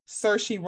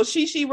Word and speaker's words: Saoirse: Yes, if you can say Saoirse Ronan, I be mispronouncing her name like off principle. Sorry to that lady. I mispronounced it. I'm like, so Shishi Ronan, that's not her name, but you can say that.